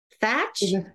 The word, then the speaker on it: Thach: Is it Thach? Yes, they are tech. You got it.